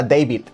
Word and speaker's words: Deiby: Entonces, obviamente será David, pero no se escribe David, sino que ella lo escribió D, E, I latina, B, Y.